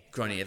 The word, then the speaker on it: Grenier: Grenier, that's how you say it.